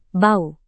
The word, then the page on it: bau: indonesian